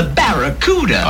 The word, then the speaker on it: Barracuda: Hey, man, the name of the Plymouth Fastback is the Barracuda. – I can't pronounce Bacaruda. – Yeah, well, look, man, try this. Say back. – Back. – Ra. – Ra. – Coo. – Coo. – Da. – Da. – Now put it all together.